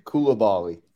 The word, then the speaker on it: Coulibaly: Coulibaly.